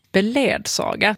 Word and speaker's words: beledsaga: Beledsaga. Där anger de faktiskt det första uttal, normaluttag, som beledsaga.